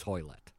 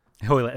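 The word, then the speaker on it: Hoilett: Well, apparently, Hoilet is Hoilet. – Hoilet, that's right.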